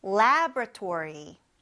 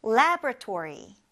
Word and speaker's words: laboratory: 그래서 스펠링을 보면은 LAB, lab이고, 그 다음에 O가 나와서 laboratory 하고 싶은데, labo라고 하지 말고, lebra. 그냥 바로 RA로 건너뛰어서 하시면 됩니다. 그래서 laboratory라고 발음해요. 근데 이게 단어가 너무 기니까 일상생활에서 사람들이 대화할 때는 그냥 줄여서 lab, L-A-B, lab이라고도 하니까 그것도 잊지 마세요.